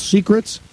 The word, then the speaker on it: Seacrets: A week from Monday, the 19th of August, when we broadcast live from the beach, we will be at a hangout called Secrets. – Yeah, but it's spelled S-E-A-C-R-E-T-S.